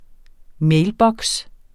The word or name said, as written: mailboks